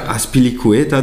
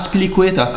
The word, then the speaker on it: Azpilicueta: Azpilicueta, Digga. Das erste Mal, dass ich das richtig ausgesprochen habe. Azpilicueta.